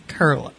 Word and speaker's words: Koehle: So our guest is Orlean Kerhle. Her last name is spelled K-O-E-H-L-E. And I won't do that again, but it is tricky because it doesn't spell like it sounds. And people usually pronounce it incorrectly.